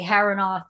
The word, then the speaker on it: Harinot: we just switched it to Harinoth. So it's actually pronounced Harinot, but for we Americans, we just say Harinoth, just make it easier.